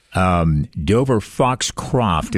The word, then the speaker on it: Dover Foxcroft: Dover Foxcroft is the town. – Yes. – It was once pronounced Dover Foxtrot. By yours truly.